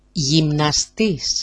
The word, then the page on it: jimnastis: jimnasti-s